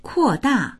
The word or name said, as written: kuo4da4